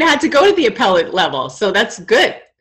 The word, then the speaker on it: appellate: You know, even in the appellate level, I can't even believe I pronounced that wrong. – appellate.